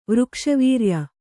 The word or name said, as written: ವೃಕ್ಷವೀರ್ಯ